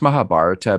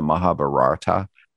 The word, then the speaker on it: Mahabharata: It's pronounced Mahabharata, but a lot of times people mispronounce and call it the Mahabharata.